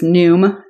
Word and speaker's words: Noom: But one that was pretty good was Noom. Noom has an interesting – How do you spell that? – N-O-O-M.